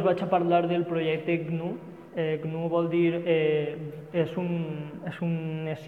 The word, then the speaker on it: GNU: que se pot pronunciar GNU com l'animal.